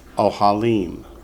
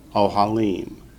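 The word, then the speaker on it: ohalim (אֳהָלִים): And in the plural, it's ohalim. So ohel, ohalim. The accent shifts from the first syllable to the last when you go from singular to plural. That's why there's no accent mark over ohalim. Remember, if there's no mark, the accent's on the last syllable.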